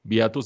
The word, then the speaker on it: Beato: Beato se escribe Beato como el beato de la Iglesia Católica.